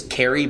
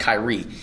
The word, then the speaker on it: Khary: His name is spelled K-H-A-R-Y. You think it's Kerry, but it's pronounced Kyrie.